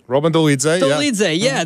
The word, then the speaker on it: Dolidze: Daukaus returns for the first time since a fight with Dolidze. Am I saying that right? – Roman Dolidze. – There you go.